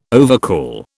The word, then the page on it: overcall: British English